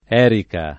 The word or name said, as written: erica